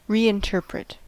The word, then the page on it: reinterpret: American English